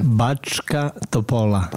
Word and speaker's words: Bačka Topola: How do you say it again? – Bačka Topola. – Bačka Topola. – So oh, bravo.